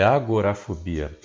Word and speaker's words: agorafobia: O que é agar agarofobia? É isso mesmo? A pronúncia assim? – é agorafobia. Agora é uma, é uma palavra em grego que quer dizer praça pública, espaço aberto.